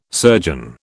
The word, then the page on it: surgeon: British English